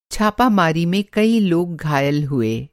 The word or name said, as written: छापामारी